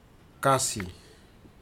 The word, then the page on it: kasi: futuna aniwa